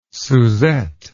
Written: susette

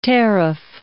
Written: tariff

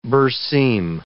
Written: berseem

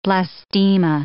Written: blastema

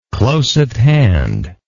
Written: close at hand

